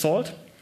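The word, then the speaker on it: NaCl: It uses a networking and cryptography lib, pronounced SALT, and it uses elliptic curves for the Diffie-Hellman key exchange, 255-bit public keys.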